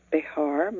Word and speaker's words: Behar: But we don't pronounce it that way in English. We just say Behar, most of us. Some people say Behar, and some people say Behar. But I like Behar. It's very easy to spell. Just write bear, you know, like grizzly bear, B-E-A-R.